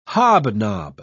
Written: hobnob

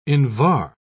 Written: invar

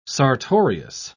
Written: sartorius

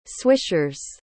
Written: swishers

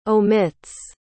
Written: omits

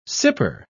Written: sipper

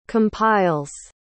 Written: compiles

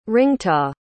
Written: ringtaw

